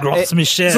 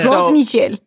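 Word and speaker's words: Gros Michel: From here, that looks like Gross Michael. – It sounds French or something. – Yeah. – Gross Michel. – Gross Michel.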